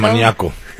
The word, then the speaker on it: Monaco: In Monaco. It's Monaco, not pronounced. – There's no any there. – It's Monica.